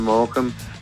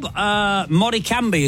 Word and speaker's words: Morecambe: Hi, Morecambe. – Hello. Moricambi, as people pronounce it when they see it written down for the first time, don't they?